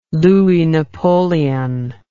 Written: Louis Napoleon

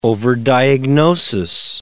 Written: overdiagnosis

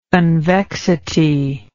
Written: convexity

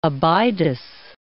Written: Abydos

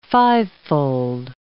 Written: fivefold

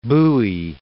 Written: Bowie

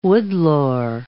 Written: woodlore

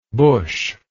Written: bushlike

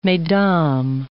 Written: mesdames

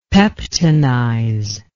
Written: peptonize

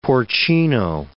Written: porcino

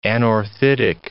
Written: anorthitic

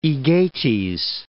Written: Aegates Islands

